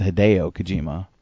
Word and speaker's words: Hideo Kojima: It's actually pronounced Hideo Kojima.